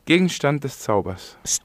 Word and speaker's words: Gegenstand des Zaubers: Gegenstand des Zauber, sí. Es la traducción. – ¿Lo pronuncié bien? – Muy mal. – Diritual. – Gegenstand des Zaubers.